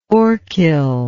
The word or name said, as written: orchil